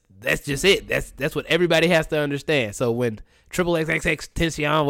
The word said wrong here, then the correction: XXXTentacion: whatever his fans, I don't know how to pronounce his name. I know I'm saying it wrong. – Yeah, no, it's XXXTentacion, but I mean, I don't fucking care how you say his name.